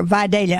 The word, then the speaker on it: Vidalia: Did I say it right that time? – Yes, they were Vidalia onions.